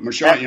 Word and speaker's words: Marchand: John, so Marchand, or I'm not sure how you say his name, but Marchand, you know, that's correct, and I know where you're going with this.